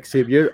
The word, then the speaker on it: Xavier: Pero la cosa es de que acá decidieron ponerle Javier porque la X es como J, el sonido, y bueno, es uno de los sonidos. A mí se me hacía lo más normal, yo no supe que se pronunciaba Xavier hasta, creo que hasta las películas, lo cual tiene mucho sentido.